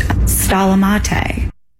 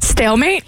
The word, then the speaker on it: stalemate: I'll go first. Stalamate. – Stalamate? – Stalamate. – Stalamate. – Stalemate? – Yes.